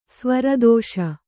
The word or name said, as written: ಸ್ವರದೋಷ